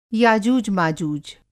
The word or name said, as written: याजुष माजूज